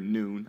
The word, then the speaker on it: Nun: So in Numbers 13:8, it says he's from the tribe of Ephraim, Hoshea, the son of Nun, or Nun. It's pronounced in Hebrew as well. So you'll hear Nun. I'll say Nun, but we're not thinking Catholic Nun.